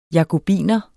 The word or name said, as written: jakobiner